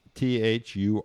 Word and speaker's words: Thuringian: It's spelled, pronounced just like it's spelled, Thuringian.